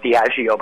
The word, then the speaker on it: Diageo: Another point, one quick thing, is it's pronounced Diageo, by the way.